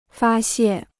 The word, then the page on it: 发泄: fā xiè